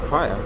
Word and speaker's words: Paea: I've just told you my name is Pia. – Pia, okay, yeah. So how do I spell that? – P-A-E-A. – P-A-E-A.